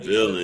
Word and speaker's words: Villon: My bad, not Villain. Yeah. I just googled it. Yeah, Villain. It's weird. It's spelled like V-I-L-L-O-N.